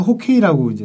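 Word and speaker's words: 호케이 (хоккей): 해방 이후에 둘 다 빙구라고는 쓰지 않는데 북한에서 호케이라고 이제 썼잖아요. – 아, 호케이. – 이게 러시아식 발음이라고 합니다. – 하, 아, 네. 키를 러시아시로 발음하면 호케이가 된다 이렇게.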